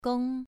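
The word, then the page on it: gong: tone 2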